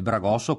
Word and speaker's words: bragosso: Beh, le tipologie principali qua nell'Alto Adriatico è il bragosso, come abbiamo detto, io lo pronuncio, come dicevo anche l'altra volta, la chioggiotta, e quindi non con le Z ma con la S, ed era lungo attorno ai 12 metri, 36 piedi veneti.